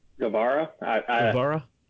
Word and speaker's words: Guevara: Sammy Guevara, I think it's pronounced. – Guevara? Yeah.